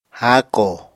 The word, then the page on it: háakò: apatani